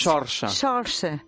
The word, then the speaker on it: Saoirse: Para mí es Saoirse. – Vale, ya. Es que yo decía Saoirse. – Es irlandesa.